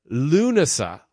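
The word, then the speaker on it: Lughnasadh: I'll do my best Irish attempt at Lunasa. – Lunasa. – Yes. – Yes, that's right. Okay. – Yes. – And I can see how I whiffed it. – Lunasa.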